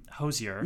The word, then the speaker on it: Hozier: And it's you and Hozier.